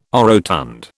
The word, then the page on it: orotund: British English